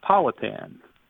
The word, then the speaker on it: Powhatan: So the correct way to say is Powhatan.